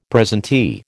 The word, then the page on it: presentee: American English